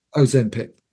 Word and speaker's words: Ozempic: Ozempic. – Ozempic, yeah.